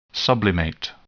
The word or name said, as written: sublimate